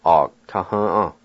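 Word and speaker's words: agatahva: The A with the G is silent in this word. A, G, ta, h, a. A, ta, h, a.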